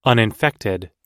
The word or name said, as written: uninfected